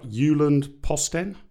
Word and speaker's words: Jyllands-Posten: Jyllands-Posten? – Yeah.